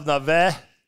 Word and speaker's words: Nefesh: It's Tzion Leshem of Nevesh. Is that the right pronunciation? – It is.